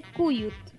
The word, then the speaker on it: cuyut: Coyote se parece bastante a como se dice en español. Se dice cuyut.